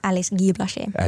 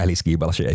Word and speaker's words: Alice Guy Blaché: Mitä lausussa nyt oikein se? – Ää, Alice Guy Blaché. – Alice Guy Blaché, joo.